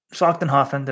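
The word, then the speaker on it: Schlachtenhaufen: Helen Schlachtenhofen. Did I say that right, John? – Sounds about right.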